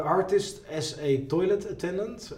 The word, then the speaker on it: Artist as a Toilet Attendant: Ik heb hier nu bijvoorbeeld Artist's Toilet Attendant. Zeg ik dat goed? – Ja, dat boek heet Artist as a Toilet Attendant.